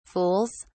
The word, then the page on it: fools: British English